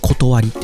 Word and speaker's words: 理: 理科の理と書いて、これ断りって読むんですね。